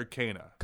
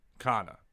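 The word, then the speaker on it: Canha: Is it Kana or Kana? – Kana.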